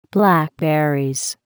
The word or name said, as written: blackberries